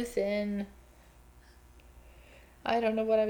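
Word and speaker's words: Luthen: I just realized you said Lutheran. What is his name? Luthin. Luthin.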